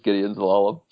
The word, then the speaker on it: Gideon Zelalem: Remember, we had the big row over here of, is it Zelalem or Zelalem or whatever. – Yeah. – So I think we have an answer from the guy who helped discover him. – Yeah, I'm pretty sure it's Gideon Zelalem.